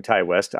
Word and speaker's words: Ti West: Well, we're calling him Ty West. I've never heard it said out loud ever. – Well, I mean, yeah, I mean, everybody I've ever heard say his name calls him Ty, but it might not have been official sources now that I think about it.